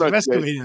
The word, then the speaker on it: Vescovi: He changed the pronunciation. It's Vescovi now.